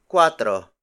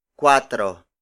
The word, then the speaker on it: kuwatro: The next number is cuatro. Cuatro. Cuatro. Cuatro is spelled as K-U-W-A-T-R-O.